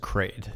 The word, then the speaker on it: Kraid: But then you get to, how do I pronounce it? Kraid or Crad? – I think it's just Kraid.